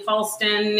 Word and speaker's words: Paulston: My name is Julie Paulston.